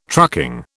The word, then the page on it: trucking: British English